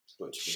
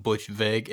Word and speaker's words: Butch Vig: Butch Vig. – Shout out Butch Vig. Is that how you say it? Butch Vig? – It's like Vig. Like Vig. – Like V-I-G.